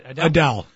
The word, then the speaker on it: Adele: Adele.